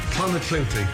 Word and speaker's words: Clonakilty: Clonakilty. – Clonakilty.